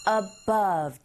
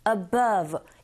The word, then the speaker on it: above: Above. 이 발음이 사실 아주 쉬운 것 같지만 또 쉽지만은 않습니다. B 하고 V 한꺼번에 다 나오기 때문에 일단 첫 번째 above 강세를 이 부분에 주시고요. above 하시면 되고 the largest lake in South 하고 America 연결되면 South America 이렇게 들리게 되죠.